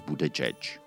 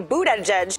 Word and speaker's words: Buttigieg: údajný polyglot a 38-letý otevřený homosexuál Pete Buttigieg. Začněme tím, že se ne vždycky stává, že i moderátoři v amerických médiích mají problém se správnou výslovností příjmení jednoho z kandidátů. Pete Buttigieg. Pete Buttigieg. Pete Buttigieg.